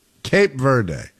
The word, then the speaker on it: Cape Verde: Is it Cape Verde or Cap Verde? Cape Verde.